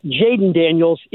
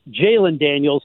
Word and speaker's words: Jalen Daniels: It's not Jaden Daniels, it's Jalen Daniels.